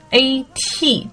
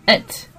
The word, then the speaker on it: at: 大多数人会念成什么 at at。我不说这个念的话一定说是错误的哈，只是说更多的我们在这个口语里面听到的，会把这一个 a t 这个 at 念成一个 it it。就是相对来说，你的嘴型没有那么大，而是比较扁平的这个什么，呃，OK，it，it，然后呢，它算是一个弱读的现象哈，OK，像这种实词，比如说 school 啊，或者说像这种啊 he 呀这种主语啊，一般都不会弱读的。